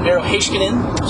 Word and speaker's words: Heiskanen: It's kind of funny how we use the terminology in a preseason game, because tonight was not Miro Heiskanen's NHL. Miro Heiskanen? Sorry, I want to make sure you pronounce it correctly. – Let's get it right.